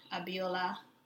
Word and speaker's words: Abiola: And Facebook is at Abiola Makanjuola, and it's spelled A, B as in Bravo, I, O, L as in Ladder, A.